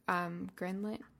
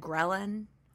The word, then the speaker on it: ghrelin: Is it ghrelin or gremlin? – I've always said ghrelin. Ghrelin. That's what I've always said. – There are all these words that I feel like we know really well, but we have no idea how to say them. – Because we only read them. People don't say, how's your ghrelin today? I think it's ghrelin. I'm going to say ghrelin.